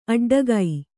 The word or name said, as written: ಅಡ್ಡಿಗೆ